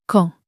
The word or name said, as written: quand